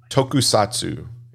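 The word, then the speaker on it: tokusatsu (特撮): Uh, tokusatsu. Is that right? – Yes. – Yep. Okay. So uh, just jump in any anytime, Brad, because I know you're. So tokusatsu means special effects and it's a Japanese term for live action film or television drama that makes heavy use of special effects.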